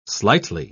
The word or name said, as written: slightly